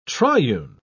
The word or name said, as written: triune